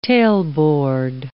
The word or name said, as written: tailboard